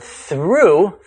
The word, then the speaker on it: through: Add a T to the end of though for thought, and the O-U-G-H sequence now rhymes with awe. – If we were to add an R to though, creating the word through, the O-U-G-H pronunciation changes to rhyme with true.